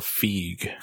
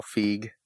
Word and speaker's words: Feig: Paul Feig. – Paul Feig.